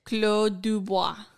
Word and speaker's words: Clos du Bois: How do you pronounce this? – Clos du Bois.